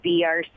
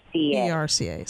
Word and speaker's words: BRCA: forgive me, but the correct pronunciation is BRCA. – BRCA, sorry.